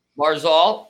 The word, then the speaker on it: Barzal: Barzal.